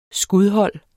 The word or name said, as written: skudhold